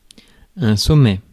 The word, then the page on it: cap: /kæp/